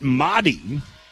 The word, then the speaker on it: Mari: You say it Mahdi, you spell it M-A-R-I.